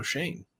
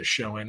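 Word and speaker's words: Schoen: By the way, producer Adam says Joe Shane is now, his name is pronounced Joe Shane, is what the Giants say. Other people say Showin. It's one of those last names people will never agree on.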